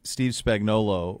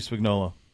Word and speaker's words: Spagnuolo: Steve Spagnuolo, yeah. – Yeah, I wouldn't have read it. – I wouldn't have put the emphasis on the O.